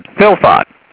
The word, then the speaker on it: fylfot: So I thought F-Y-L, and then fought. I'll do, let's see, fought rhymes with lot, then got, and hot. So I'll say fought is F-O-T. Let's try that. So I said, philfot, F-Y-L-F-O-T, philfot.